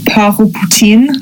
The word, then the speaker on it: Port-au-Poutine: How would you say it if it was sort of like French? – Port-au-Poutine.